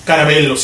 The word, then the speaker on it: Carabello: Carabello, sí. Carabello se pronuncia. Carabello es para, para, pues que.